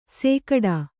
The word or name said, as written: ಸೇಕಡಾ